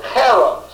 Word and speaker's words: cherem: It's the word cherem, C-H-E-R-E-M, cherem, pronounced with a cherem.